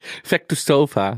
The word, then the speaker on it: Vestikova: En ging helemaal niet meer over beauty blenders of Masha Vestikova. Ik kon niet uitspreken. – Vestikova.